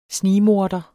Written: snigmorder

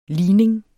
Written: ligning

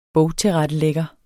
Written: bogtilrettelægger

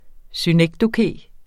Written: synekdoke

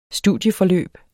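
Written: studieforløb

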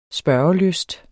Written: spørgelysten

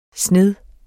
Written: sned

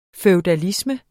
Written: FEUDALISMEN

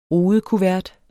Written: rudekuvert

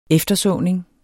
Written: eftersåning

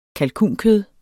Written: kalkunkød

